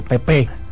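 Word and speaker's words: Pepê: con acento en la E Pepe ajá Pepe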